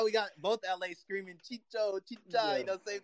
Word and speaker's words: Chicho: Or it's going to be Chucho, right, if I'm saying it correctly? – Chicho. – Sorry, because I'm used to Chicharito, you know, so Chicho.